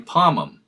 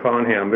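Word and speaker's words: Pomham: I would have to go with what I'm saying, Palm Ham, because Palm Ham, it sounds like there's two M's in the word, and actually there's only one M.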